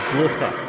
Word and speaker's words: Blücher: Blücher.